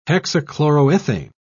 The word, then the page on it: hexachloroethane: British English